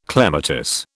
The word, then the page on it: clematis: British English